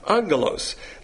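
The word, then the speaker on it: angelos (ἄγγελος): So then it says, the second part of this paragraph, the word for heaven is uranos, things into which angelos, when you see a double G in the Greek, it's pronounced with an N, angelos,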